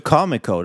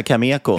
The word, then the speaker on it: Cameco: Säger du Comico? Det låter som Comic Con. – Ja, jag, jag, brukar säga Cameco.